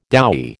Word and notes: doughy: American English

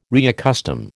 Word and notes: reaccustom: American English

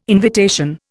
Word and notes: invitation: Indian

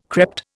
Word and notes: crept: Indian

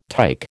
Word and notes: tyke: American English

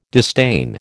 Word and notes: disdain: American English

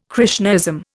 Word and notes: Krishnaism: Indian

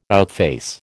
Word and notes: outface: American English

